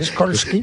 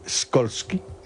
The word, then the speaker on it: Skolsky: No, señor, no es SH, es SKOLSKY. – Impronunciable. – A la señora... – Raskolnikov. – ¿Cómo se pronuncia? Porque es polaco. ¿Cómo se pronunciaría en polaco Skolsky? – Skolsky. Como tú lo has dicho.